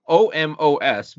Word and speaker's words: Omos: spelled O M O S, but it's pronounced Omos, I guess.